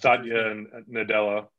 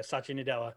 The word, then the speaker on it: Satya Nadella: Satya Nadella. – There you go. Satya Nadella.